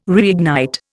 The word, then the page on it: reignite: Indian